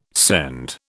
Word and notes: scend: British English

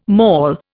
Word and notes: maul: Indian

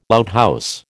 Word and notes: outhouse: American English

